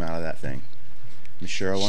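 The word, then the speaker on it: Michele: Leah Mitchell. – Michelle. – Sorry. Leah Michelle. I've been saying it wrong this whole time. I've always thought it was Mitchell.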